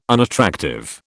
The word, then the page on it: unattractive: British English